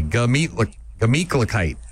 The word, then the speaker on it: Gemütlichkeit: I'm going to get you to pronounce it properly. Here we go. Gah. – Gah. – Meat. – Meat. – Kla. – Kla. – Kite. – Kite. Glamit. – Not a gla. Gah. Just G-A. Gemütlichkeit.